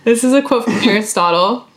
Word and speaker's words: Aristotle: Aristotle, that's how you say it? – Yeah.